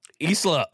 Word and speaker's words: Isla: I don't know how to pronounce her name and I'm probably going to butcher it, but it's Isla. Is it? – Isla.